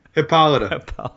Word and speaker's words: Hippolyta: Hippolyta. – Hippolyta.